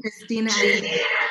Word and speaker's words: Christina Aguilera: And that Christine Aguilera, is that how you say her name? – Christina Aguilera.